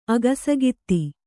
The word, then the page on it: ಅಗಸಗಿತ್ತಿ: agasagitti